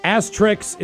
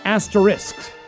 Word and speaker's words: asterisk: Asterix is incorrect. But I do like asterix, depending on who does them. The correct pronunciation is asterisk.